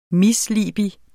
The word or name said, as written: misliebig